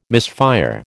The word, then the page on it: misfire: American English